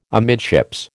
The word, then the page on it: amidships: American English